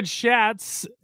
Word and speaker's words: Schatz: Kevin Schatz from the Join the Bandwagon podcast. – Welcome to the Bandwagon. And it looks like Schatz, but it's actually pronounced shots, like shots of tequila, whatever.